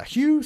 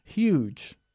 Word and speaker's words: Hugh: Ojo, no se dice huge. Siempre me hace reír cuando hablan del actor inglés Hugh Grant y dicen aquí Huge Grant, que literalmente sería Grant enorme, que me hace gracia.